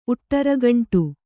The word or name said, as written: ಉಟ್ಟರಗಂಟು